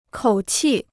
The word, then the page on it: 口气: kǒu qì